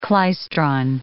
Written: klystron

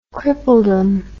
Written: crippledom

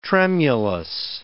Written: tremulous

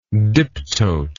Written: diptote

